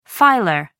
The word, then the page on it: filer: American English